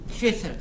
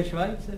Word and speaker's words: Schweitzer: No to se vyslovuje Švajce.